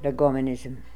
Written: Legomonism

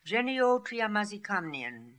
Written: Geneotriamazikamnian

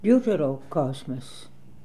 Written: Deuterocosmos

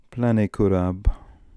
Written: Planekurab

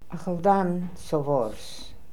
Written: Akhaldan sovors

